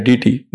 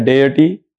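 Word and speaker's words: deity: डीटी नाम का कोई प्रोनाउंसिएशन इंग्लिश भाषा में है ही नहीं। एक है इंग्लिश, एक है ब्रिटिश प्रोनाउंसिएशन, एक है अमेरिकन, एक है ऑस्ट्रेलियन, तीन प्रोनाउंसिएशन है। हम लोग भारत में फॉलो करते हैं ब्रिटिश को, उसको कहा जाता है डेयटी या डेयटी या देवता को डेयटी कहते हैं, डीटी तो कहते ही नहीं।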